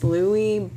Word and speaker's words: Lewy: But he died at age 69 after a battle with dementia with, I don't even know, how do you say this? – Lewy Body Dementia. – Lewy Body's Dementia. That's the first time I ever heard of it. L-E-W-Y.